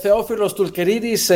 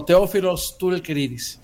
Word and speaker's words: Theophilos Toulkeridis: En griego es Theophilos Toulkeridis, en español sería Teo o Theophilos Toulkeridis.